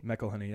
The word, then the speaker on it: McElhenney: McElhenney, yeah.